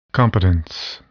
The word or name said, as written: competence